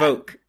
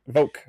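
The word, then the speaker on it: Voq: Is that Vork? – Bad. Vok. – Vok. Okay.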